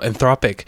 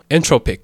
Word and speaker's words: Anthropic: 然后这间公司呢， A-N-T-H-R-O-P-I-C，不是念作 entropic 或是 entropic，我不知道大家怎么念，反正我听过很多种念法，但它的真正念法呢是 anthropic。